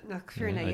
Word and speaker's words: Knockfierna: Knockfierna, yeah.